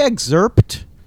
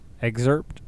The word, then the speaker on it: excerpt: Do you say excerpt? – Excerpt? Excerpt? – Do you pronounce the P? – Do I? – I'm just curious, yeah. – I don't know what I do. – Exert. I guess that's like exerting effort. Excerpt.